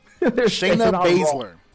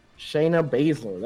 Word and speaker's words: Baszler: Shayna Baszler. – Shayna Baszler, there you go.